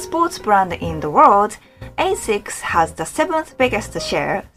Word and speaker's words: ASICS: So, if your Japanese friend is making a confused face like this, try pronounce it ASICS. That ASICS.